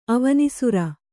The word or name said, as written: ಅವನೀಸುರ